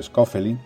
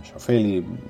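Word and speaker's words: Schaufeli: Publicaciones en español, un referente es el profesor Pedro Gilmonte, también el profesor Schofield y Schofield, mejor pronunciado, ¿verdad?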